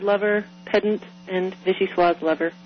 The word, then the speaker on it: vichyssoise: Because of the final E. – Right. – In that word. – Right, so it's vichyssoise.